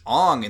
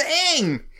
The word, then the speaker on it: Aang: And he's like, oh, well, obviously Aang's name would be pronounced Ong in the culture it's based off of. And it's like, but it's a TV show where they say his name all the time and it's Aang.